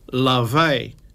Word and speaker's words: Laufey: So this song that I'm going to play for you now comes from Lavey, spelled Laufey, L-A-U-F-E-Y.